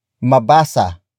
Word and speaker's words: mabasa: Word for word it's mabasa, kaya, ni, Brian, ang sulat kamay ko mabasa kaya ni Brian ang sulat kamay ko mabasa kaya ni Brian ang sulat kamay ko The first word is mabasa. Mabasa. Mabasa. Mabasa is spelled as M A B A S A.